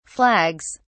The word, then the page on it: flags: British English